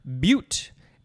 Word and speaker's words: beaut: Now we have the word beaut, B-E-A-U-T. I believe there's another word that is pronounced the same way, B-U-T-T-E, but this one is basically the beginning of the word beautiful.